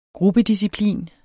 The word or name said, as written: gruppedisciplin